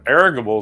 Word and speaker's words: irrigable: That irrigable is the actual pronunciation.